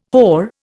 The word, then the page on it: whore: Indian